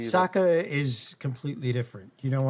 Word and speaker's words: Ahsoka: So the Dave Filoni movie is going to be a culmination of Mandalorian and I'm not going to mispronounce her name again. – Ahsoka. – Thank you. I don't know why I stuck on Osaka. Ahsoka.